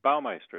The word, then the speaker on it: Baumeister: And am I pronouncing it wrong or right? Baumeister? – Baumeister is correct.